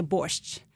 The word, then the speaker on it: borscht: So in Ukrainian, we usually say borscht.